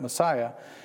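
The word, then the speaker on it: Masaya: Not Messiah like our Messiah, but it's spelled differently, means differently, but Messiah.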